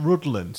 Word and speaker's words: Rhuddlan: Now there's a place called it's a place called Rhuddlan, spelled R H U double D, and the double D in Welsh is is uh, pronounced like a the, and Lan.